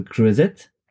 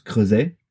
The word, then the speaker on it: Creuset: Actually, it's a French brand and it's pronounced creuset. It's not because there is a T at the end of the word that you have to say it. It's like a croissant and not a croissant.